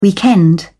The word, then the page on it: weekend: British English